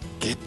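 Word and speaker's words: 夏油: 本日は下湯ヴィレッジ株式会社共同代表の村岡陽子さんにお話を伺います。よろしくお願いします。 – よろしくお願いします。 – よろしくお願いします。 – 下湯ヴィレッジ、下湯温泉、はい、夏の油と書いて下湯って読むんですよね、はい、なかなかな読める方が少ないというね。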